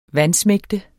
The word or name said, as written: vansmægte